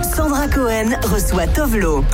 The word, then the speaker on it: Tove Lo: The exact is Tove Lo. – Tove Lo.